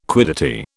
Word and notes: quiddity: British English

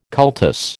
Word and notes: cultus: American English

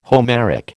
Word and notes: Homeric: American English

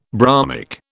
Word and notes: bromic: British English

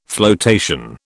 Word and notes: floatation: British English

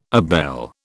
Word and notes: abele: British English

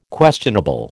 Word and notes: questionable: American English

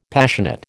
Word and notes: Passionate: American English

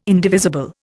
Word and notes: indivisible: Indian

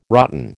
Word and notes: rotten: American English

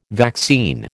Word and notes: vaccine: American English

